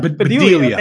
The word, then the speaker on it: Bedelia: Bonnie Bedelia? Bedelia.